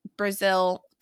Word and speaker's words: Brazeal: And it's Brazeal, pronounced like the country, but spelled Brazeal.